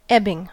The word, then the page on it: ebbing: American English